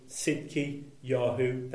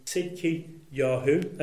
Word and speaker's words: Tzidki Yahu: And in Hebrew, Zedekiah is Tzidki Yahu. That is with a silent T. Tzidki Yahu.